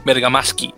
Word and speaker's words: Bergamaschi: Entonces en relación al entrenador, en este caso estamos hablando de Emiliano Bergamaschi o Bergamaschi, dependiendo cómo lo quieran pronunciar, pero Realmente se pronuncia Bergamaschi, ya que es un, apellido italiano.